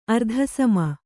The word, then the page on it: ಅರ್ಧಸಮ: kannada